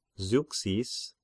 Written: Zeuxis

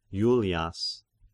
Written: Julias

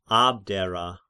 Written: Abdera

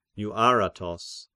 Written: Euaratos

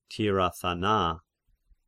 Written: Tirathana

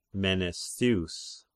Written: Menestheus

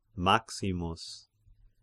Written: Maximus

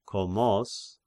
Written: Komos

